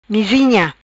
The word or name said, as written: Mizinha